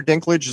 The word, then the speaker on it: Dinklage: And I was just talking to Jason about what's Peter Dinklage, is that name right, Jason? – Yes.